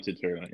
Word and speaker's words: Turturro: Yeah, John Turturro.